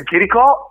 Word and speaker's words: Chiricò: Sì, io nella mia semplicità ho il mio profilo personale Instagram e Facebook, nome e cognome Cristian Chiricò, con l'accento sulla O, perché ogni volta mi chiamano Chirico ma invece c'è l'accento